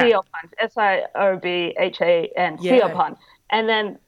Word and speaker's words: Siobhan: The teacher goes, Siobhan, and no one answers. And she's like, Siobhan? And then this girl goes, is she Bonhan? – Oh, no.